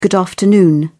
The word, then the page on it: good afternoon: British English